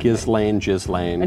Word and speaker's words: Ghislaine: Okay, how do you say it? – Ghislaine. – Okay, so maybe I've been saying Ghislaine. Okay. – Ghislaine. – I just need to get rid of the S altogether. Ghislaine, yeah.